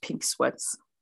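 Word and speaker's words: Pink Sweat$: Honestly, I think it's just Pink Sweats.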